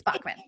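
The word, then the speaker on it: Backman: And if you don't have time to read Frederick Bachman, is it Bachman or Bachman? Bachman. It's Bachman. – B-A-C-K.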